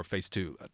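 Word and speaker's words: PHSE II: And it's a local band we will listen to, a duo called Phase 2, P-H-S-E-I-I, or Phase 2. That's how you pronounce it, you told me.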